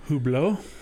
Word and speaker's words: Hublot: That watch company, Hublot, I don't know how to say the damn name. – Hublot? – There we go.